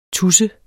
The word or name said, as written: tus